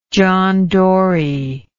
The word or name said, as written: John Dory